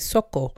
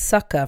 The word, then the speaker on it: Soco: It's funny because it's spelled S-O-C-O, so I want to say Soko, but it's Soko. Featuring Wizkid, Caesar, Millie and Spotless Terry.